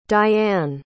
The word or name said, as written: Dyanne